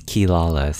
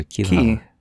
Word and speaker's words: Kilala: It's Kilala. So, Ki like Ki-E-Y and then Lala. So, Kilala.